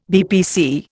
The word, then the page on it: BPC: Indian